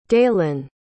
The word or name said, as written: daelyn